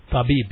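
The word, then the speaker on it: tabib (طبيب): Tabib, yes, that's correct.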